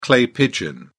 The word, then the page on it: clay pigeon: British English